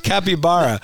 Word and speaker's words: capybara: Well, maybe I'm saying it wrong. It's capybara. C-A-P.